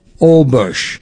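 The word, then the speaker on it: Holbox: and we're going to a place called Olbox. It's H-O-L-B-O-X. H is silent, so it's O, and B-O-X is pronounced bush, like a B-U-S-H.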